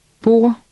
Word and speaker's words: bord: In Danish, D is silent when it follows L, R, or N. Try to read word 6. – Hånd. – Read 7. – Bord.